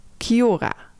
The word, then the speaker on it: Kia ora: Kia ora spreek je uit als Kiora.